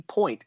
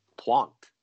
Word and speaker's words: Pointe: But it's French, so it shouldn't be Point. It should be Pointe.